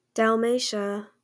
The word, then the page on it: Dalmatia: dælˈmeɪʃə; -tiə